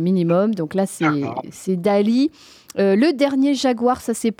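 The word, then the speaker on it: Daaaaaalí!: Le nouveau Quentin Alors Dali, hein, voilà, si je prononce bien, il y a 6A.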